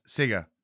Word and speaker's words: Sega: Sega.